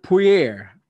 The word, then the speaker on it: Poirier: Dustin, the Diamond Poirier,